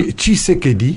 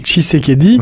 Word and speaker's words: Tshisekedi: Vous avez m'aidé à prononcer Tshisekedi, c'est comme ça. – Tshisekedi. – Tshisekedi, ouais.